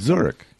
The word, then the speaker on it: Zurich: This one is from Roberto Moreno in Zurich. Is that how you pronounce Zurich. – It? Zurich.